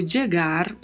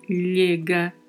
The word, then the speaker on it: llega: Atenção para a pronúncia do duplo L, com aqueles sons que já comentamos anteriormente. Llega, llega, chega, llega, são algumas das sonoridades para este duplo L.